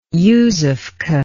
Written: yuzovka

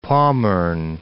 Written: Pommern

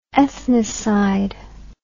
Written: ethnocide

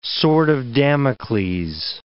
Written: sword of Damocles

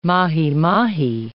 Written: mahimahi

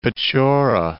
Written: Pechora

